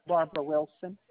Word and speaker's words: Barbara: Barbara Wilson. – Can you spell that for me, please? – B-A-R-B-A-R-A. W-I-L-S-O-N.